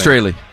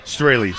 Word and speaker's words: Straley: Straley. – Straley.